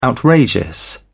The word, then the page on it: outrageous: British English